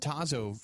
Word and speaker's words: Tazo: I mean, they had Tazo for years.